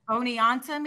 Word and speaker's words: Oneonta: Okay, if I say this wrong, Oneonta maybe.